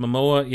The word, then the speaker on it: Momoa: First, Momoa, yeah, and he's going to be Aquaman.